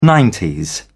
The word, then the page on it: nineties: British English